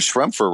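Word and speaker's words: Schroepfer: So Mike Schremfer, what does Schremfer rhyme with? – Oh. – Kempfer.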